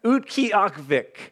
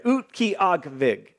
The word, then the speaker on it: Utqiagvik: It's Utqiagvik is how it's pronounced. I've said that like 25 times this week to make sure I could say it right. Utqiagvik.